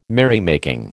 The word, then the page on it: merrymaking: American English